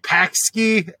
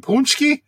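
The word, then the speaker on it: pączki: And they're like, Paxki? And I'm like, you mean Punchki?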